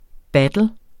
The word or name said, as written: battle